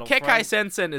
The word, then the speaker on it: Kekkai Sensen: No, Kekkai Sensen is literally four syllables. That's easy. Kekkai Sensen. Two of them are repeating syllables.